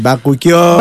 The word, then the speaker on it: Bakuchiol: Bakuchiol λέγεται, έτσι προφέρεται, παιδιά. Βαρέσει. – Μπαρι, Bakuchiol.